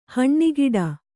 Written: ಹನಿಗೂಡು

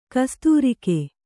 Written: ಕಸ್ತೂರಿಕೆ

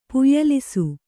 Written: ಪುಯ್ಯಲಿಸು

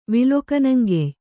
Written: ವಿಲೊಕನಂಗೆಯ್

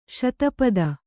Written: ಸ್ಥಪತಿ

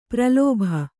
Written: ಪ್ರಲೋಭ

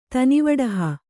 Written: ತನಿವಡಹ

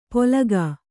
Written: ಪೋಲಕಾ

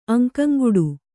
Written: ಅಂಕಂಗುಡು

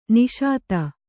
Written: ನಾಶ್ತಾ

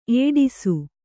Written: ಏಡಿಸು

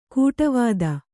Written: ಗಾಡಿವತಿ

